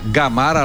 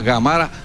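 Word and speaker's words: Gamarra: Εμείς το μάθαμε Γαμάρα, αλλά προφέρεται Γαμάρα, τον περίφημο στόπερ, συμπαίκτη του Ντέμι Νικολαίδη, ο οποίος σχολιάζει για την τηλεόραση.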